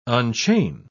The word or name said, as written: unchain